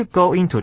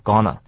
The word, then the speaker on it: gonna: Are you going to try it? 啊，当然那个 it 不能念这么重了。我们稍微分析一下 ,are you, 念作 are you, 念 er 的声音 ,you 念 er 的声音，因此连起来变成 are you, 非常轻。 going to, 应该很多人都知道，不会念 going to, 念作 gonna。